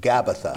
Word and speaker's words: Gabbatha: But in the Hebrew, Gabbatha. – No, it's Gabbatha.